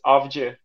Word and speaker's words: Avci: It is Avci spelled. It has a very Turkish pronunciation.